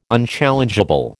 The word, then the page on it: unchallengeable: American English